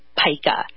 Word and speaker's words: pica: They call it pica. Doesn't rhyme with Zika.